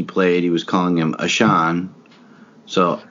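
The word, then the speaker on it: Ahcan: Jack Akan is 23. – Apparently, I've learned it's pronounced Ashan.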